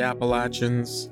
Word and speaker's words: Appalachian: It's pronounced Appalachian. And if you don't say it like that, they will string you up and beat you to death. They are very, very picky about the way you say that mountain range, apparently.